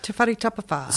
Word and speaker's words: Te Whare Tapawhā: So we're talking about Mason Jury's Māori health model, Te Whare Tapawhā. Did I say that right? – Yep, Te Whare Tapawhā.